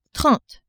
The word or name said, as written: trente